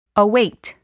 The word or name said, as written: await